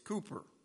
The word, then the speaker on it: Cowper: A hymn written by a man by the name of William Cooper, even though it's spelt C-O-W-P-E-R. I used to always pronounce it Cowper, but apparently it's not Cowper, it's Cooper.